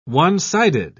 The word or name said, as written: one-sided